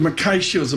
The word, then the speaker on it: Mackay Shields: Eric Kaczynski joins us with Mackay Shields. Am I pronouncing that right? – You got it. – Mackay nailed it.